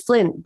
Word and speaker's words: Flint: I do, because my surname is Flint, but it's not spelt with a Y.